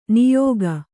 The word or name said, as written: ನೆಯಿಗೆ